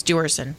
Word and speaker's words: Duerson: The family of David Vuerson. Is that how you pronounce this football player's name? V-E-V-U-E-R? – Duerson, actually. – What is D. – it? – That's a typo. It's Duerson.